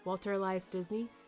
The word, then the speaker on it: Walter Elias Disney: Walter Elias Disney.